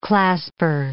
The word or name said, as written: clasper